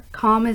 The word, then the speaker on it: Khalm: Khalm is spelled K-H-A-L-M, and it's a mix of the state of being Khalm.